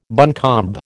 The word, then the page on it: buncombe: American English